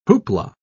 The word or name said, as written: hoopla